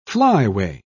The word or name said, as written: flyway